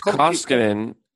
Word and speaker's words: Koskinen: They have the young Koskiemi. There's so many names like that in the NHL now. And I know I'm butchering these names. – So it's Koskinen. Koskinen. He's not young, though.